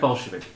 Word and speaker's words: Bolshevik: It's pronounced Bolshevik.